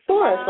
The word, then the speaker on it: Doris: Doris, like in Doris Day.